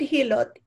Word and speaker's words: Hilot: Am I pronouncing it well? – Hilot.